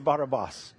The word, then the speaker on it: Barabbas: Barabbas, it's Barabbas, as we've come to say, but it should be pronounced Barabbas.